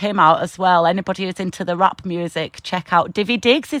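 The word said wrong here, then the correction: Daveed Diggs: It's David Diggs.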